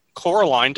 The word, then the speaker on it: Coraline: There's actually a scene where Chloe is reading Coraline to Trixie. – Thank you for pronouncing that the correct way, by the way.